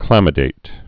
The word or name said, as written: chlamydate